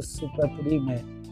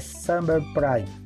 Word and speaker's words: subprime: Vamos falar portuguesado, superprime ou sub ou Prime. é subprime a pronúncia.